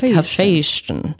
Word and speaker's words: Hephaestion: I think it's Hephaestion, like Sebastian, but like Hephaestion.